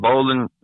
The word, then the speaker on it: Bolin: It's called Bolin, like spelled like a bowling ball, Bowling, Bolin Funeral Home, Rocky Mount, North Carolina.